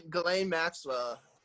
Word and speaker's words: Ghislaine: How you pronounce her name? – Ghislaine Maxwell.